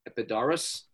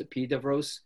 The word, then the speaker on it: Epidaurus: I went to the opening night of the ancient theater festival in the ancient site that's called, it's spelled Epidaurus. It's pronounced Epidavros. It's got a huge,